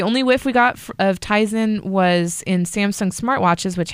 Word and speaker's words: Tizen: Samsung and Intel's operating system Tizen. Am I saying that right? Is that how you're saying it right.